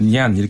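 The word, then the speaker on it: 니안 (年): 그, 예, 그러니까 니안 이렇게 발음을 하는데 예.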